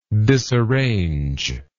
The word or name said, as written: disarranger